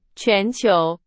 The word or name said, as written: 全球